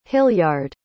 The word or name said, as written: hilliard